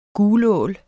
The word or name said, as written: gulål